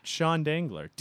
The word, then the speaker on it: Dengler: D-E-N-G-L-E-R, because everyone thinks Dengler is spelled D-A. – Why would they think that? – Because I say Dengler and I do not pronounce my last name correctly, I guess. Yeah. – And you spell Sean? – S-E-A-N, the correct way.